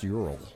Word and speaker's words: Ural: It's actually pronounced Ural. – Ural. – Ural. – Ural. – Ural. – Ural. Say it like that.